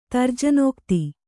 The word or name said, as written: ತರ್ಜನೋಕ್ತಿ